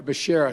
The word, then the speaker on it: Becerra: For Secretary of Health and Education Services, I nominated Javier Bakiria. You know, Javier Becerra, excuse me.